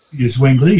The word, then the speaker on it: Zwingli: How do you say it? Zwingli. – Zwingli, yeah.